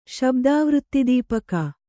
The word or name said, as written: ಶಬ್ದಾವೃತ್ತಿದೀಪಕ